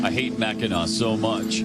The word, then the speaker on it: Mackinac: Why do they spell it Mackinac when it's Mackinac?